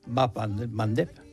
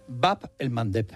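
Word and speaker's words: Bab el-Mandeb: Bap el Mandeb. – Bap el Mandeb, esa me la voy a apuntar. – Cuesta un poquito pronunciarlo, por eso en Occidente se dice el Mandeb.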